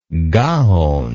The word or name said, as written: gaonic